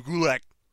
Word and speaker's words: Gulak: Shout out to Drew Gulak. – Oh, man. – Cool. – It rhymes with attack.